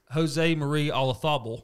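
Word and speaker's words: Olazábal: And he was playing against Jose Marie Olazabal.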